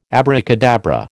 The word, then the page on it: abracadabra: American English